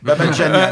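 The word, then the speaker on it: Babadjanian: et le trio en fa dièse mineur d'Arnaud Babadjian. Et à ma grande honte, je l'ai bien prononcé ? – Presque. – Babadjian.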